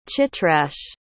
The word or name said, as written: chitraksh